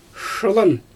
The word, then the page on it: ʃɯlan: Xyzyl